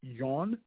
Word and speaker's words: yuan: Am I pronouncing that correctly? – Yen. – Yen? They spell it Y-U-A-N. – Yen. – It's not Y-E. – Right. No, it's still yen. It's still yen. – That's not spelled yen. – Oh, I'm sorry. It's yuan. It's yuan.